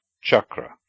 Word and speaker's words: chakra: C, cha, is like the ch in church. Never like k, as in car, like s, as in scent, or like sh, as in chevron. For example, chakra, wheel, chit, consciousness, and vach, speech.